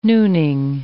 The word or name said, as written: nooning